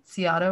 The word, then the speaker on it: Scioto: is that how you say it? Scioto River. – Scioto.